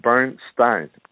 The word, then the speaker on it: Bernstein: Number one, this is the 100th anniversary of the birth of, and you're going to have to tell me the right way to pronounce his last name, Bernstein. – No, it's Bernstein.